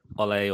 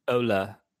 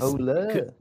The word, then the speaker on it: Ole: Interestingly, uh, um, everyone pronounces his first name wrong, don't they? Because everyone says Ole or Ollie, yeah, and it, it's actually Ole? Ola. Is Ola.